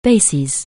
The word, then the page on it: basis: anglais